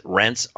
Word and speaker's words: Reints: This comes from a Fortune magazine and was posted on Yahoo Finance, a story by Renee, I'll pronounce it Rents, R-E-I-N-T-S.